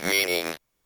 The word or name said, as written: meaning